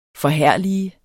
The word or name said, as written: forherlige